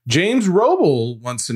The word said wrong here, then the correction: Robles: He even put in here, my last name's pronounced Robles.